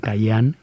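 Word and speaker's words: cajún: Pues muy bien, pues otro día te explicaré la diferencia entre cayún, que se pronuncia cayán, y cideco, que se pronuncia Sadeco o sadeco.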